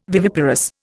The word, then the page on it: viviparous: Indian